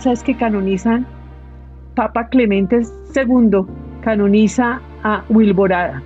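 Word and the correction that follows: Wiborada: Viborada realmente. Se escribe igual pero sin la L.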